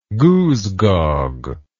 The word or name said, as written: goosegog